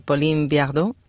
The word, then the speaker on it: Pauline Viardot: Estabas hablando de Pauline Viardot, no sé si lo estoy pronunciando bien. Sí.